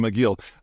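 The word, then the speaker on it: Aqil: A-Q-I-L. I think it's pronounced Aguil, like a chew, but Aguil.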